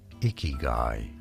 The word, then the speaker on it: ikigai: We are looking at how to pronounce ikigai. Ikigai.